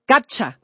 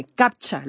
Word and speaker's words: CAPTCHA: CAPTCHA, perdóneme, CAPTCHA, lo dije mal.